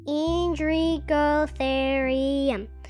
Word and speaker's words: Indricotherium: Indrigo You say it like this Indricotherium.